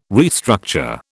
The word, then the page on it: restructure: British English